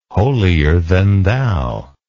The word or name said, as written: holier than thou